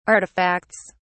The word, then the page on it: artifacts: British English